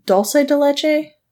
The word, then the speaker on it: dulce de leche: Is it dulce de leche? – Dulce de leche. – Dulce de leche? – Yeah. – I always think it's dulce because there's not an H.